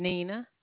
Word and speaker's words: Nina: My name is Nina. – Nina? – Yeah. – How'd you spell that? – N-I-N-A.